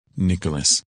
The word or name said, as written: Nicholas